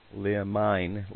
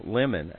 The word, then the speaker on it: limine: It's spelled L-I-M-I-N-E. Lemine. Lemon, actually, is the pronunciation.